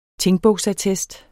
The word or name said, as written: tingbogsattest